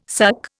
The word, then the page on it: suck: Indian